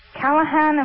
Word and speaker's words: Callahan: All of this based on Dr. Roger Callahan. Am I saying his name correctly? – I believe so.